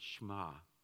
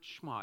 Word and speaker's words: Shema: Often translated here is the word Shema. It's S-H-E-M-A-H, but we kind of drop the E as we pronounce it.